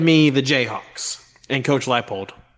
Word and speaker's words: Leipold: Now it's my turn to correct you guys. It's Lance Leipold.